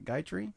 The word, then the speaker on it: Gayatri: The Gayatri mantra? Is that all right? – Did I say that right? Gayatri? – Mm-hmm. Yeah.